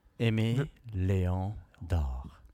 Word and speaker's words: Aimé Léon d'Or: Aimé Léon d'Or.